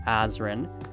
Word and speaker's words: Azren: It is at Polyglot Azren.